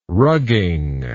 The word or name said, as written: rugging